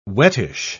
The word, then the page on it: wettish: British English